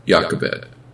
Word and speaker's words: Jochebed: In the Hebrew pronunciation, the J takes on a wide sound, Jochebed.